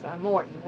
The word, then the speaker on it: Morton: Moulton. – Spell that last name for me. – M-O-R-T-O-N. Moulton. – Oh, Morton. – Morton. – A.B. Morton, okay.